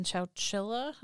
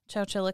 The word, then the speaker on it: Chowchilla: She is currently housed in the Central California Women's Facility in Chowchilla. Am I saying that right? – Yep. – Chowchilla, California.